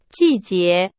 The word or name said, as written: 季节